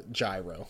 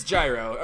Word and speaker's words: Gyro: Yeah, the easy way to look at it is look at the kana, fortunately. And it is, in fact, Gyro. Like, phonetically, it is Gyro Tsiperi.